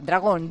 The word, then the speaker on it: Dragon: ¿Cómo se dice en inglés? ¿Cómo se pronuncia? Dragon. Pues Dragon suena bien, suena potente. Dragon Musk. Mi hijo Dragon.